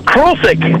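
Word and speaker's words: Krulcik: Oh, Krulsik. It's, you want me to spell it? – Yeah. – It's K-R-U-L-C-I-K.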